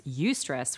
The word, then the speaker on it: eustress: Eustress, which is spelled E-U, stress. Eustress is like euphoria, EU, that same euphoria.